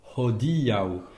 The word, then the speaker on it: hodiaŭ: Alors attention à l'accent tonique sur hodiaŭ. Il y a en fait dans le mot hodiaŭ, il y a plusieurs difficultés. Il y a le H aspiré. Ho. Il faut qu'on l'entende. Il y a trois syllabes. Ho, di, au. Et donc l'avant-dernière, c'est le i. C'est le di, pardon. Hodiaŭ.